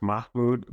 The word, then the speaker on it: Mahmoud: So the case is pronounced Mahmoud versus McKnight.